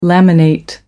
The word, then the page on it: laminate: American English